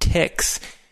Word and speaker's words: TikZ: It's the primary graphics package for LaTeX is a package called TIX. At least that's how I say it. It's spelled T-I-K-Z.